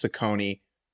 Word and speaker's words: Cecconi: Just so everyone knows, C-E-C-C-O-N-I. Okay, that first C is going to be an S. That second C is going to be a K, and that's going to help you pronounce Ciccone correctly as we move forward, because he is a relevant character.